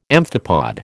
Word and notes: amphipod: American English

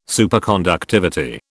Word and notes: Superconductivity: British English

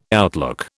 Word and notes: outlook: British English

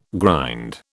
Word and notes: grind: British English

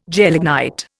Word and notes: gelignite: Indian

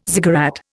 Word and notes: ziggurat: Indian

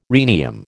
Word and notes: rhenium: American English

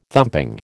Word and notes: thumping: American English

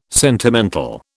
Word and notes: sentimental: British English

